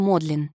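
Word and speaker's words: Magdalen: Вопреки написанию, его название издавна произносится как «Модлин».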